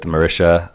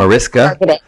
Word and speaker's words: Mariska: If you follow Anjelah on Instagram, she has a love affair with SVU, with Marisha, how do you say her last name? – Mariska.